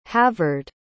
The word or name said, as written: havard